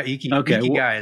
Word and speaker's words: ikigai: I thought it was ikigai. It's ikigai? – One of those. Yeah, ikigai is how I've always said it.